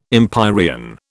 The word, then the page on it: empyrean: British English